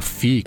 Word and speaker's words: Phi: plataforma FI, que se escreve PHI e que está patrocinando aqui o episódio de hoje também do Loop Matinal.